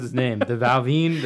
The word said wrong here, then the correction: Balvenie: Balvenie? – The Balvenie. – Balvenie.